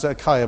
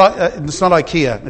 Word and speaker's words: Achaia: That's just to remind me how to pronounce Achaia, by the way. Okay, all right. It's not IKEA.